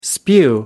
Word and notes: spew: American English

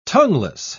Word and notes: tongueless: British English

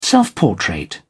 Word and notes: self-portrait: British English

